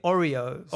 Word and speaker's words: Aurier: I feel like I'm just pronouncing a cookie every time. A biscuit. Oreo.